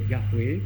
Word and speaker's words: Yahweh: Son nom devait se prononcer sans doute en hébreu Yahweh.